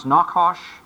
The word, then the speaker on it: Nahash: And you remember, we went in the story and we showed you how in Hebrew it's pronounced Nakash. Nahash is Nakash.